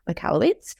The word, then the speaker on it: Michalowicz: which is obviously by Mike Michalowicz. And I think I pronounced that correctly. – You did, yes.